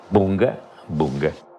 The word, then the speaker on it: Bunga bunga: Bunga, bunga.